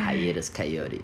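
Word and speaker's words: Hiatus Kaiyote: Hiatus Coyote.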